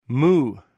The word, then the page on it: mou: greek